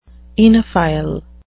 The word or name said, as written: oenophile